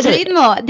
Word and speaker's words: ritmo: So it's spelled R-I-T-M-O, which to me is ritmo. – Ritmo. – But ritmo. – Yeah, you got it. – See, so you, it's like a silent T. Ritmo.